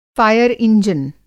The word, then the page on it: फ़ायर इंजन: فائر انجن